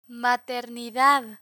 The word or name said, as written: maternidad